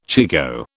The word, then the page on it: chigoe: British English